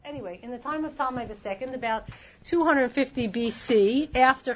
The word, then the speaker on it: Ptolemy: The Ptolemy, spelled with a P, P-T-O-L-M-E-Y, got Egypt, North Africa.